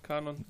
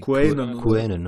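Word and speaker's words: QAnon: wie das überhaupt so ausgesprochen? Kanon, Quainen? – Nee, Quainen, ne? – Quainen.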